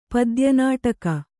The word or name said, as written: ಪದ್ಯನಾಟಕ